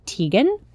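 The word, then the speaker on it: Tieghan: Her website is called Half Baked Harvest, and her name is Teagan. I think that's how you pronounce it. It's T-I-E-G-H-A-N.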